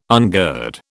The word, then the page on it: ungird: British English